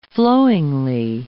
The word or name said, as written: flowingly